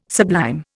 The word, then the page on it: sublime: Indian